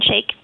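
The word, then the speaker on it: Shaich: Which was kind of the thrust of the article in the New Yorker today with your founder, Ron Saich? Is that how you say it? – Ron Shake. – Shake. Thank you.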